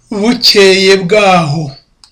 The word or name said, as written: bukeye